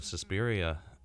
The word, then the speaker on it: Suspiria: Yeah, while we're on the subject of Suspiria, did I say that right? – Yes.